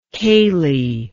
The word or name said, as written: Cayley